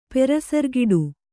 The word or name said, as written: ಪೆರಸರ್ಗಿಡು